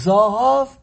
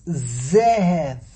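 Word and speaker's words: Zehev (זהב): Hashem will give us ornaments of Zohov, but the way Le'osilove we're going to pronounce Zohov is not Zohov. It's Zehev. Zehev. Not Zohov.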